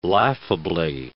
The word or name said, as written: laughably